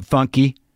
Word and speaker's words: Fonky: The Ever Fonky, Fonky. It's how it's spelled, F-O-N, but funky.